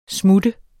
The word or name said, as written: smutter